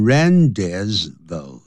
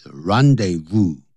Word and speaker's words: rendezvous: 他这次演讲比赛的时候他得了第一名 First place, second place 也是一样的前面都不能加的 The Moulin Rouge was the rendezvous、这个是不能念成 rendezvous 这不是英文不是英文是 rendezvous